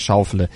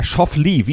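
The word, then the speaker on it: Schauffele: Ja, also die Amerikaner sagen Xander Schaufele und er ist deutschstämmig und sein Vater hieß sicher mal ursprünglich Stefan Schaufele. Und das haben die Amerikaner natürlich dann entsprechend umgeändert in der Aussprache.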